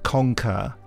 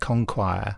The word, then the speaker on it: conquer: Also, when I was very young, when I first saw the word conquer, as in Daleks conquering, I because of the Q and the U, I was pronouncing it conquire. – Yeah, but you would, wouldn't you? But you would, because it's... – Well, I don't know why I was saying ire. There's no I in it.